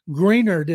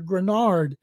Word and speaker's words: Greenard: Stroud, but other players, Nico Collins, Will Anderson Jr., Derek Stingley Jr., I hope Jonathan Greenard, as I keep hearing him pronounced, people hear Grenard, that he's able to come back and play and add some more money onto that new contract that's coming.